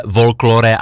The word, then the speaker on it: Folklore: Dokonce od režisérky, od ženy, Viktorie Štěpánové, ten film se jmenuje, ono se to píše Folklore, ale prý se to vyslovuje klasicky folklor.